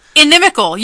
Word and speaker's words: inimical: inimical? – You've said it right both times.